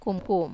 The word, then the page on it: kum-kum: wamesa